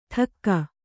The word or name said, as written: ಟಕ್ಕ